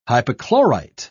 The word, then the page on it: hypochlorite: British English